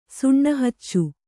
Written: ಸುಣ್ಣಹಚ್ಚು

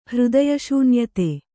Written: ಹೃದಯಶೂನ್ಯತೆ

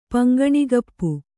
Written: ಪಂಗಣಿಗಪ್ಪು

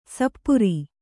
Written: ಸಪೂರ

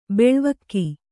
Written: ಬೆಳ್ವಕ್ಕಿ

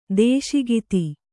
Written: ದೇಶಿಗಿತಿ

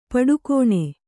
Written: ಪಡಿಗಾಣ್

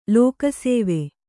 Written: ಲೋಕಸೇವೆ

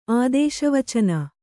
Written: ಆದೇಶವಚನ

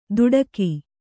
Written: ದುಡಕಿ

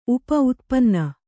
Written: ಉಪ-ಉತ್ಪನ್ನ

